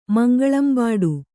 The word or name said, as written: ಮಂಗಳಂಬಾಡು